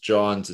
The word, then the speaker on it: Jawns: Lager, but John's is spelled J-A-W-N-S.